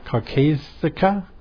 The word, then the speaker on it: caucasica: And Apis mellifera caucasica. How do you say that? I can't. Caucasica. People are probably... – Yeah, Caucasica.